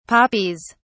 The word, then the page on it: poppies: British English